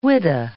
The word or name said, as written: whydah